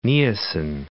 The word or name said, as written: Niassan